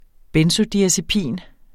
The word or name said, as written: benzodiazepin